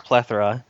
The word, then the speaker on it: plethora: Now, I would have said plethora. That's how I would have pronounced it. But Mike said plethora. Now, which is correct? – I think yours is. I've probably just been pronouncing it wrong all my life. – All right. Well, are we agreeing with plethora? – It's pronounced plethora.